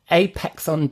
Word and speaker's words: Apexon: Tell us a little bit about yourself and what Apexon do. I've pronounced that correctly, right? – Yes, Apexon.